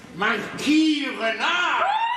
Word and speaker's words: Marquis Renard: Nein, ein Herr, ein gewisser Makör Renoir, so heißt er. – Ja, du meinst den Marquis Renard? – Ja, was schreien Sie, das kann man so auch aussprechen, wie ich das ausgesprochen habe.